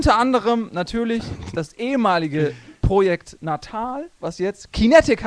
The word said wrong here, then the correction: Kinect: heißt. – Falsch, nee. Du hast das schon falsch gesagt. – Das habe ich falsch gesagt. – Kinect. Kinect, verdammt.